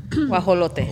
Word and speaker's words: guajolote: Guajolote.